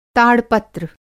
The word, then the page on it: ताड़पत्र: Hindi